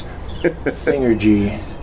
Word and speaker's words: Thingergy: I hope I say it right. It's not Thingergy, is it? – It's Thingergy. No, it's Thingergy.